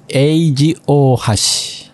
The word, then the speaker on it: Eiji Ohashi: My name is Eiji Ohashi.